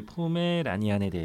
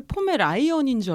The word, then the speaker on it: 포메라니안: 이 포메라니안에 대해서 오늘은 잠깐 조금 말씀을 좀 드릴까 합니다. – 네, 근데 저도 사실 포메라이언인 줄 알았어요. – 네, 네, 이게 발음이 좀 어렵죠.